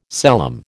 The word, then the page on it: celom: American English